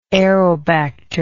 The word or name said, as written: aerobacter